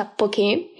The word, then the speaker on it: poke: Stiamo parlando del poke, il poke tra parentesi si pronuncia con l'accento sulla E, io in precedenza ho sempre sbagliato, pronunciavo sempre poke, ma in realtà no, si pronuncia poke, è un piatto di origine hawaiana letteralmente significa tagliare a pezzetti ed è proprio di pezzetti di pesce che si tratta.